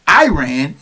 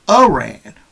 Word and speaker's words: Iran: have called the country Iran instead of Iran, which is a proper pronunciation.